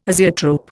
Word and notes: azeotrope: Indian

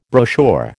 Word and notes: brochure: American English

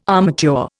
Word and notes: armature: Indian

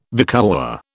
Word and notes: vicuäa: British English

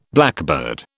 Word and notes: blackbird: British English

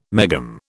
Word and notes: megohm: British English

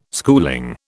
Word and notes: schooling: British English

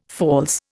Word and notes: false: Indian

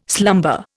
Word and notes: slumber: Indian

